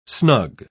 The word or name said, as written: snug